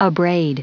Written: abrade

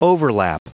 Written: overlap

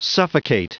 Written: suffocate

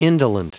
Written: indolent

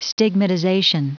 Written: stigmatization

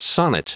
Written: sonnet

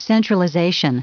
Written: centralization

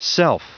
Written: self

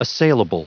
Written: assailable

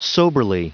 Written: soberly

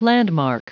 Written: landmark

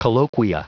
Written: colloquia

